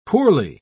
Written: poorly

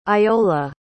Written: iola

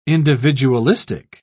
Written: individualistic

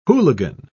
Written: hooligan

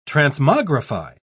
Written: transmogrify

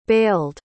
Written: bailed

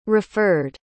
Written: referred

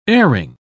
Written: airing

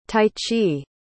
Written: taichi

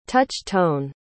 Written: touch-tone

